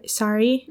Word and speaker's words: sari: and in the Indian culture, they often wear sari? – Mm-hmm.